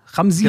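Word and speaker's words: Chamsin: Sag mal, wie entsteht da denn äh, der, wie heißt das, Ram, Ramsin? Genau, Betonung auf dem I.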